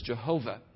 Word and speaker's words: Yahweh: Yahweh is probably the more correct pronunciation. The more familiar one is Jehovah, for the Old Testament name of God.